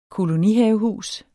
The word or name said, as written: kolonihavehus